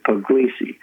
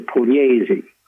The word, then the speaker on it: Pugliese: And if you see it printed in English, most people will pronounce it Pugliese. But it's Italian, and he says it's Roger Pugliese.